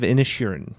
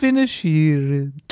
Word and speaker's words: Inisherin: Banshees of Inisherin. – No. – Sorry, I said that wrong. Ah, the Banshees of Inisherin.